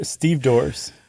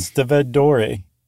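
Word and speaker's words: stevedore: That's what they call themselves in The Wire, Steve Dores. – It's been a long time since I saw that season. – That's where I learned how to pronounce it correctly, actually. – Instead of Steved Dore.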